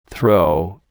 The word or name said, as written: throw